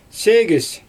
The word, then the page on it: seegIsJ: Xyzyl